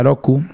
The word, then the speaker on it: Roku: Entonces, Roku, se escribe Roku, se pronuncia Roku, significa seis en japonés.